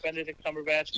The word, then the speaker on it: Cumberbatch: Did I say his name right? Benedict Cumberbatch. – You sure did.